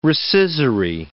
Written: rescissory

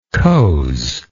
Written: coze

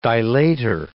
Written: dilator

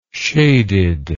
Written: shaded

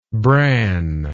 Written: branner

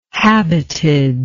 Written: habited

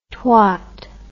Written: twot

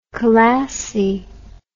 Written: khalasi

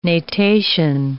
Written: natation

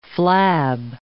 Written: flab

